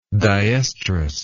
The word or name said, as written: dioestrus